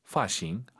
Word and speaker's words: 发型: F 발음을 좀 어려워하세요. 파싱, 파싱 아니고 파싱, 도파 아니고 도파. 정확히 F 발음 소리 많이 내야 됩니다.